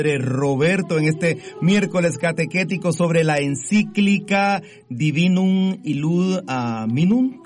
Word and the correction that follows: Divinum Illud Munus: ¿Cómo se pronuncia, padre? – A ver, es que no, ¿dónde la tenés? – Aquí la tengo. – A ver, dice Divinum Illud Munus.